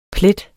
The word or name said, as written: plet